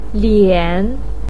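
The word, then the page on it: lian3: pinyin; tone 3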